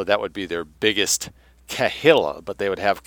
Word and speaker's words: Kehillah: K-E-H-I-L-L-A, Grips, New York. Rule of the Jewish Kehillah, Grips, New York. And I looked up the pronunciation, and that's what they're saying it is, Kehillah.